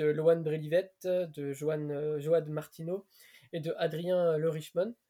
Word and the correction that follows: Le Richemont: le Richemont, j'espère que je le dis bien. Le euh, Richemont, je crois que c'est juste Richemont. Le Richemont, pardon.